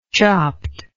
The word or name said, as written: chopped